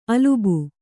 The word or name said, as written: ಅಲಬು